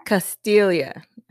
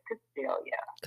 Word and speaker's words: Castiglia: Castiglia. – I was going to say Castella. – Would you say Castiglia? Well, my husband's Italian, so in Italian, the G is silent, so that's why it's Castiglia.